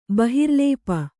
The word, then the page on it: ಬಹಿರ್ಲೇಪ: bahirlēpa